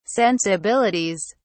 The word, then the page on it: sensibilities: British English